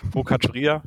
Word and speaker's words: Focaccia: Foccia Ceria. – Ja, wir haben zwei italienische das Standorte ausgesprochen. – Frankfurt, Focaccia, genau.